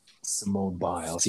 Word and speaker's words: Simone Biles: Simone Biles, yes, and Naomi.